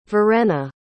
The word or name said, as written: varenna